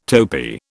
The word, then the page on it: topee: British English